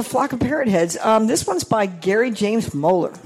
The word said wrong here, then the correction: Moeller: Is it Miller? – Yeah. M-O-E-L-L-E-R. Digitally recorded like that, but you pronounce it Miller.